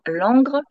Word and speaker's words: Langres: Et je précise, je prononce bien Langres, parce que quand je le dis rapidement, les gens croient Londres. Ils disent « Ah, oh, génial !» Alors, Langres, ce n'est pas tout à fait Londres, mais ça a été génial.